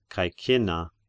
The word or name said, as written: Caecinna